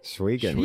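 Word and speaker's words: Schweigen: I don't want to say it wrong. I'm probably going to. Schwiegen? – Schwiegen. – Yeah. Nice. It is.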